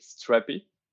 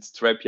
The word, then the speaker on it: Strapi: yeah, we, we, we pronounce it Strappy. Um, okay but yeah, if you want to, to, to pronounce it Strapi, yeah, it's okay. Um, it's Strapi, and it comes from from um, Bootstrap your API.